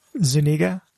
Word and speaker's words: Zuniga: Zuniga.